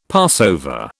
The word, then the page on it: Passover: British English